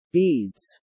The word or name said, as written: beads